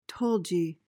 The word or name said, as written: tulgey